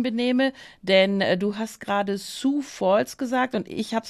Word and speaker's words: Sioux: Aber es wird Sioux, einfach Sioux, wie französisch unten oder genau.